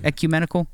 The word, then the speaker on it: ecumenical: Am I saying that word right? Ecumenical? – Yeah, brilliant. Well done.